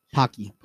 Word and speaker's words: Pocky: We have chocolate almond crushed pocky. That's how you say it, right? – Pokey. Yeah. Pocky.